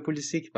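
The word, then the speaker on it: Pulisic: همه این تلفظ درسته تلفظ آمریکایی داره که خودش میگه پولیسیک و یه تلفظ هم هست که در واقع اصلیتش به کرواسی برمیگرده اون تلفظی هم که علی میگه درسته جفتش درسته در اصل خب ارفان حالا ب...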